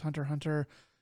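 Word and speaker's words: Hunter × Hunter: As far as I can tell, the X when it's like that is just there for looks, and it doesn't actually mean anything. It's like a silent X almost, like Hunter Hunter, I think, is supposed to be pronounced as Hunter Hunter.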